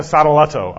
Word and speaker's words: Sadoleto: And he wrote his famous reply to Sadoleto. I would encourage you to Google that. Sadoleto is spelled S-A-D-O-L-E-T-O, Sadoleto, which still stands as one of the greatest summaries of Reformation biblical truth ever written.